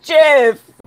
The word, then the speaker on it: GIF: I know it's not, but you also pronounce it Jif, so I don't know who to trust. – It is Jif.